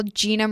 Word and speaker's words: Jeanna: mine is all Gina Marie McGee. But it's spelt J-E-A-N-N-A.